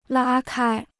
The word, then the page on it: 拉开: lā kāi